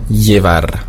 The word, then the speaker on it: llevar: Das Doppel-L wird ausgesprochen wie LJ, beziehungsweise nur wie J. Llevar heißt bringen oder tragen. Auf dem Festland wird es sehr oft ausgesprochen wie LJ. Allerdings hier, dadurch, dass wir viel Beziehungen zu Südamerika haben, sagt man einfach nur J. Also llevar.